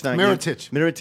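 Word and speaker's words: Mirotić: Mirotic.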